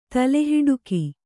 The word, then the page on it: ತಲೆಹಿಡುಕಿ: tale hiḍuki